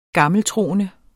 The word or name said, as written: gammeltroende